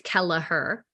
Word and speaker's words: Kelleher: I always tell them it's three syllables. It helps with the spelling. So it's Kelleher. So it's K-E-L-L-E-H-E-R.